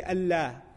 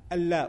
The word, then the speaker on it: Allah: If you put alif, lam, with a shadda and alif, you say Allah, Allah. But what do we say when we pronounce? Allah, we make it heavy, we break the norm of the Arabic language for this word.